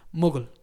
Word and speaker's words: muggle: corrígeme si lo pronuncio mal, muggle. ¿Lo pronuncio mal? – No. Está bien.